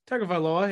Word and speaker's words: Tagovailoa: We'll start with their quarterback, Tua Tagovailoa. Is that how you say it? – Tagovailoa, yeah.